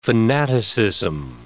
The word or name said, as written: fanaticism